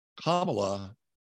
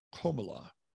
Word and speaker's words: Kamala: During remarks earlier, Biden mispronounced his number two's first name, referred to VP Kamala Harris as Kamala rather than the correct Komala.